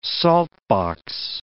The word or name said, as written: saltbox